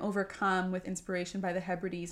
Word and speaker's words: Hebrides: So he composed his, I think it's the Hebrides. Is that how you pronounce it? Yeah, the Hebrides Overture in Scotland because he was so inspired by the landscape.